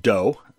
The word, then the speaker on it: DoH: It's pronounced DOH, spelled D-O-H, and stands for DNS over HTTPS, which we've talked about before, but I think we mentioned it without really fully defining it.